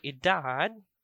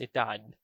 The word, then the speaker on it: edad: So instead of saying 24 años, we can also say edad 24. Edad 24. So edad literally means age.